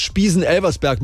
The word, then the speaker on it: Spiesen-Elversberg: Die wichtigste ist jetzt Spiesen-Elversberg mit der Betonung auf Elversberg.